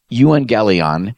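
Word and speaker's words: euangelion (εὐαγγέλιον): There's a Greek word in verses 14 and 15 that's pronounced euangelion. It's where we get our word evangelism from, euangelion evangelism.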